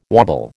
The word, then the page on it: wobble: American English